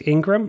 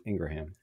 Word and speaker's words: Ingraham: Ingraham. No, I think isn't it pronounced Ingram? – No, I'm sure it is, but I like calling her Ingraham.